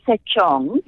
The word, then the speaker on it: Setsong: You just go to, it's pronounced Sechong, but you spell it Setsong. So S-E-T-S-O-N-G, like a song.